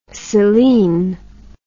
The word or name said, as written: selen